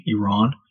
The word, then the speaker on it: Iran: That is the proper pronunciation, Iran.